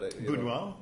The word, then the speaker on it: Buñuel: And with the famous, I can never pronounce his name right, but Brunel or something like that. – Buñuel? – Okay, thank you.